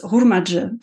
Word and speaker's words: Hurmacı: Es ist ein türkischer Name, äh, also der Nachname ist Hurmacı. Das ist mhm. die türkische Aussprache.